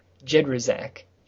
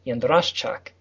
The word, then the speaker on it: Jedraszczak: It has been simplified for silly Americans down to Jedraszczak. – Jedraszczak. – Yeah. – Okay. – But the original Polish pronunciation is Jedraszczak. The SZ is a sh, and the CZ is a ch.